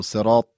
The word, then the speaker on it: صراط: مثلا اهدنا صراط، حرف طا هم همینطور صراط طا کاملا درشت تلفظ بشه که متمایز بشه از حرف س و حرف ت دو نقطه.